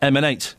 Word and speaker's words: MN8: You pronounce that MN8.